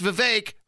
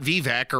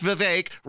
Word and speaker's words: Vivek: And it's Vivek rhymes with cake, not Vivek or whatever the hell Mitt Romney said. Mittens. That's not how you pronounce it. We know this by now. It's Vivek rhymes with cake.